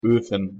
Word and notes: ofen: German

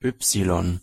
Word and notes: y: German

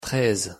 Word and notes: treize: French

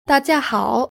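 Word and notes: da jia hao: Chinese

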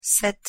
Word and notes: sept: French

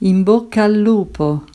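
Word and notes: in bocca al lupo: Italian

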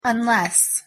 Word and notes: unless: English

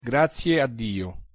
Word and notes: grazie a dio: Italian

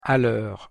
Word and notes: a lheure: French

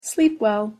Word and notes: sleep well: English

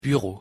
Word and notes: bureau: French

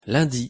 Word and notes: lundi: French

